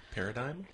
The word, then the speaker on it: paradigm: I don't even, how do I pronounce this word? – Paradigm? – Yep. – The Paradigm. – That silent G always gets me.